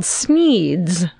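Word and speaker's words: Sneads: Okay, so I currently live in Tampa, Florida, but I grew up in a small town in North Florida called Sneeds. If they say it's Sneds, I'm going to be fucking pissed off because it's spelled Sneed. – Because there's seven E's. – It's S-N-E-A-D-S. That's Sneed.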